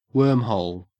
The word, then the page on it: wormhole: Australian English